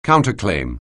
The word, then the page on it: counterclaim: anglais